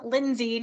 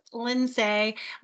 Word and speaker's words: Lyns: I am Lyns. Now I get Lyns, Lynnice, Lynsay. My name is spelled very uniquely, and so I answer to anything, and I won't hold it against you if you call me something different.